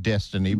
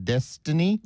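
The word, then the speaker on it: Dystany: Not well, maybe they pronounce it Destiny, but it's D-Y-S-T-A-N-Y, Destiny.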